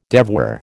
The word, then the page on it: devoir: American English